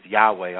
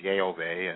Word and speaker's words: Yahweh: which means the most popular pronunciation is Yahweh, although some people say is Yehovah, and they can't make up their minds what it is. So Yahweh is really the most popular pronunciation of God's Hebrew name.